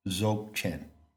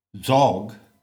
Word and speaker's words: Dzogchen: Now, another scholar has proposed that the word Dzog, which rhymes with sog and dog, in fact, it doesn't rhyme with dog, it is pronounced dog, Dzogchen.